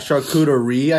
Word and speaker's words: charcuterie: Charcuterie, I think, is how you pronounce it. – Yeah, so yeah, that sounds about right. I think you put the emphasis in the right place yeah.